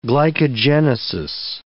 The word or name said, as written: glycogenesis